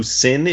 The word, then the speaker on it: Cyn: Like, your book is definitely more, I'm trying to be vague here, but the reveal of who Sin is, I'm saying that right, like C-Y-N? – Yeah.